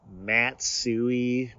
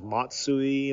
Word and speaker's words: Matsui: another one was like Hideki Matsui, when his name is Matsui, you know, the sport.